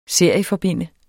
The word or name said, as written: serieforbinde